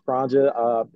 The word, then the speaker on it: Cronja: That's C-R-O-N-J-A Culture. And a lot of times too, you know, it is, that's how you pronounce Kronja. And just to make it easier, you just put the two words together, chronic and ganja, just to make it easy for everyone.